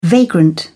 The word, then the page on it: vagrant: British English